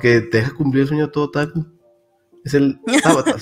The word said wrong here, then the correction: Tanabata: ¿sí se pronuncia Tanabata, ¿no?